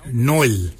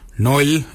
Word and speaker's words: nyooly: Nail is spelled N-G-A-R-L-Y, nail, for underarm. For your elbow, we say noil. Noil is spelled N-Y-O-O-L-Y, noil.